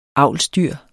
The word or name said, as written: avlsdyr